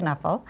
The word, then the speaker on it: Knuffle: And he's written Knuffle Bunny, it's pronounced Knuffle. Because he's Dutch. Mo Willems is Dutch.